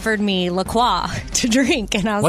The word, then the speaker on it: La Croix: And they even say on their website, it's definitely pronounced La Croix. And if you ever get confused about it, just remember it rhymes with enjoy. So enjoy your La Croix.